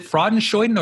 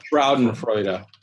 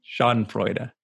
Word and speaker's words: Schadenfreude: And but what I didn't, it's what, what's the word, is it Freudenschoiden or oh man, now you're making me mispronounce it, but it's the German word for taking joy in other people's misery. – Hold on. – Schadenfreude or something.